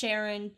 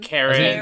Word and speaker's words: Charon: I didn't know how it was pronounced. Charon, Charon, Charon. – Karen. – You can do either one.